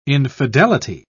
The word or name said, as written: infidelity